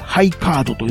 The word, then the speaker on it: ハイカード: この秘密組織と書いて、ハイカードと読むのか。